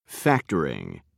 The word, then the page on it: factoring: American English